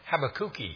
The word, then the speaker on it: Habakkuk: And I knew a missionary to Brazil where they speak Portuguese, and I said, how do they pronounce Habakkuk or Habakkuk? He said, no, they pronounce it Habakkuki. It sounds like Habakkuki.